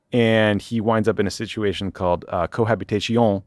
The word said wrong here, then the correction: cohabitation: why did I say it like that? Cohabitation,